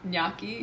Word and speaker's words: gnocchi: Gnocchi? – Yes!